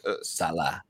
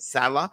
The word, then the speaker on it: Saleh: In Robert Saleh, am I saying that correctly? Or Saleh. – Robert Saleh.